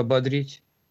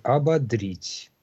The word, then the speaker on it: ободрить: В глаголе ударение на суффиксе «ободрить».